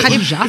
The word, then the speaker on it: Khadija: On dit Khadija. – C'est parce que K-H, ça fait Kha.